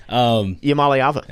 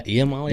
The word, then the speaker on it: Iamaleava: Yeah, and you know, Nico Iamaleava also said before the season, I feel pretty confident that I said that right, but Iamaleava. – Yeah, Iamaleava. – There we go.